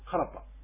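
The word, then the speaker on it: karpa: これは行ってきたよというんですけども、カラパというのは実は自分が行ってきたということで、クアラパというのがこのサルガー流域ではカラパというふうに発音されます。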